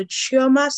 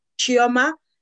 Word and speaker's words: Chioma: So Chioma. So again, I'll spell it phonetically, Charlie Hotel India Oscar Mike Alpha Chioma.